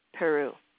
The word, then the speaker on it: Peru: But NPR says that her last name is pronounced like the country of Peru. Madeline Peru.